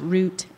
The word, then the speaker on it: route: Whole milk from Grandpa's cows instead of store-bought 2%, butter instead of mayonnaise on our ham sandwiches, newscasters who pronounced R-O-U-T-E as root instead of route, plus all the run-of-the-mill gossip we heard about people we had never met.